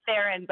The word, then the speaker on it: Theron: We pronounce it Theron, but I think it can be pronounced both ways.